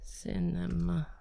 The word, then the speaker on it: Sinema: It makes absolutely no sense for him in particular and Kyrsten Sinema. I don't know. I keep saying her name wrong. I know. Is it Kyrsten? Because I have Kyrsten Gillibrand here. So. Kyrsten Sinema. Sinema. Kyrsten, yeah. Okay. K-Y-R.